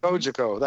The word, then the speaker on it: Cogeco: let's see if I can pronounce this right Ko, Cogeco TV? – Cogeco. – That Cogeco. Cogeco. Oh, so close. Right. I had the uh, emphasis on the wrong syllable there.